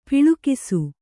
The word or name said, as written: ಪೊಳಕಿಸು